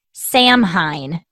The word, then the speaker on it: Samhain: And Samhain is spelled S-A-M-H-A-I-N. Looks like Samhain is what it looks like, but it's pronounced like S-O-W-E-N, Samhain.